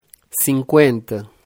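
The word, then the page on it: 50: Brazilian Portuguese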